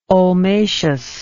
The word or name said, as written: ulmaceous